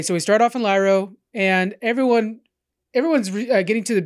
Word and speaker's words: Lairo: It's spelled L-A-I-R-O. It's pronounced Lairo, apparently.